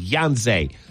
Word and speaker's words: Yhonzae: Yonsei Pierre. Edge, Alabama. Actually, I know who this kid is. You're going to see him tomorrow. Pierre is a, it's spelled Y-H-O-N-Z-A-E, Yonsei.